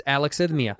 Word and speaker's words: alexithymia: It's alexithymia.